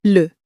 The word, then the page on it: le: french